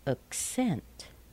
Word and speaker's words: accent: But when we use the verb, we say to accent. To accent. An accent. To accent. And the next one? An address. But to address. To address. So notice how weak the first syllable is.